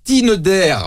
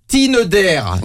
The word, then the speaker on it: Tinder: Ça s'appelle Tinder. Je prononce bien, hein. Tinder.